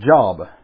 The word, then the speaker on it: Job: It's spelled like the word job, but it's pronounced Job, a long O.